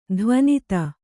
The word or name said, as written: ಧ್ವನಿತ